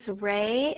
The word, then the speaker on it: Re: The last name is Ray, and it's spelled R-E.